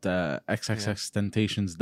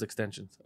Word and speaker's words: XXXTentacion: Okay, look, I thought when I first read it, I thought his name was Extensions. Okay. And then I was told, no, you have to pronounce all three X's. So his name is XXX Tentacion.